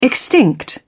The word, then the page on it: extinct: British English